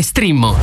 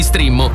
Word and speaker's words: Streammo: Entra adesso nel mondo di Strimmo. Vai su strimmo.it. Si scrive Streammo o scarica l'app.